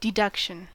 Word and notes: deduction: American English

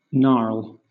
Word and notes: gnarl: British English

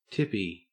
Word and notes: tippy: Australian English

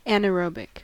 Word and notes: anaerobic: American English

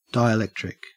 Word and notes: dielectric: Australian English

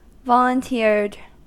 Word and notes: volunteered: American English

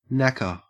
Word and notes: knacker: Australian English

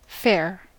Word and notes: fair: American English

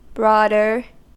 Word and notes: broader: American English

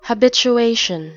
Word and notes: habituation: American English

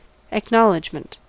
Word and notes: acknowledgment: American English

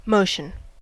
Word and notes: motion: American English

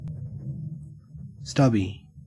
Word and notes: stubby: Australian English

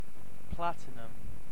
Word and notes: platinum: British English

